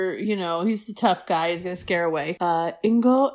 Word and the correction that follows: Inigo: how do I say his name? – Inigo Montoya. – Inigo. Okay, so Inigo is somewhere.